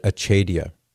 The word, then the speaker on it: acedia: Acedia is a not often discussed phenomenon, but I think many people do experience it. So some people pronounce it Acedia, maybe for the Latinists among us,